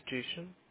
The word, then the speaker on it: Jason: My name is Jason. – Jason, I'm sorry, how do you spell it? – J-A-S-O-N.